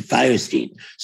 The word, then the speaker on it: Fierstein: It was my father's last name. And he pronounced it Fierstein. So I figure that's the way you pronounce it.